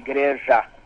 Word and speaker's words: Igreja: The way we make the J sound just like you do in English. So that would be Igreja, not like the Spanish say.